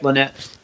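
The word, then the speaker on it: Lanett: Hey, you're originally from did I pronounce right? Lynette? – Yep, Lynette.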